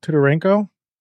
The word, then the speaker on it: Titarenko: Titarenko with his City of Shadows work.